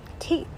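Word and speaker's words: Thích: Thich Nhat Hanh, uh, whose actual name I think is pronounced um, Teek Teek Teek Nhat Hanh, uh, a, a, Vietnamese Buddhist monk, and um, and somebody who's written some of the the wisest philosophy that I've ever read.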